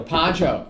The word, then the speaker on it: Pichot: Austin Pichot? – Pancho. – Pancho. – Yeah. – Pancho. Just Pancho. Silent T.